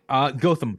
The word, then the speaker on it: Gautam: Gautam Mukunda. – Gautam, G-A-U-T-A-M, Mukunda.